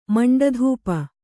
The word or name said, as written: ಮಂಡಧೂಪ